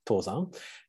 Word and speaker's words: Tozan: The latter character, Soto, the To is in Chinese way pronounced Dungshan, but in Japanese way we say Tozan.